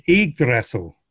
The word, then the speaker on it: Yggdrasil: Return of the Yggdrasil.